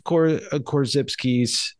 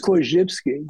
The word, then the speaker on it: Korzybski: I think it's Korzybski. – Korzybski. – I think the guy Bruce Kodish, who wrote a massive biography on Korzybski, right. – That's how he pronounced it. – Okay. – So that's I'm guessing that's the right way to.